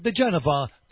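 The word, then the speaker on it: diGenova: Hold on, Gino, you're talking to Gino Giovannutti, you're talking to Joseph DeGenova, the Washington, D.C.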